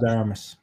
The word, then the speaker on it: Ana de Armas: Ana de Armas, according to her.